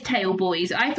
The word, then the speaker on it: Tailboys: Oh no, it is Tailboys. Because I thought it was Tails Boy, and I every time I read it differently.